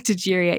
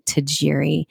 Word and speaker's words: Tajiri: and so, with that being said, you can find me at Dr. Tiffany Tajiri at YouTube. And then I have my own personal website as Dr. Tiffany Tajiri.